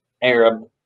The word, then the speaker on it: Arab: That's spelled Arab, pronounced Arab, in Alabama, uh, in, in a place at the foot of the mountain in Guntersville called Dog Holler.